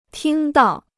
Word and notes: 听到: tīng dào

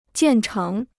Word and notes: 建成: jiàn chéng

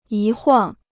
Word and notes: 一晃: yī huǎng

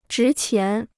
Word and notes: 值钱: zhí qián

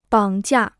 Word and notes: 绑架: bǎng jià

